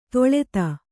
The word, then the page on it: ತೊಳೆತ: toḷeta